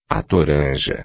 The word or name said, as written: toranja